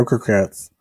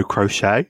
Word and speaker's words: croquettes: Potato croquettes. – I can't speak English. But I pronounced it... It was Tammy J. As potato crochet. And she's like, are you knitting it?